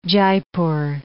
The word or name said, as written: Jaipur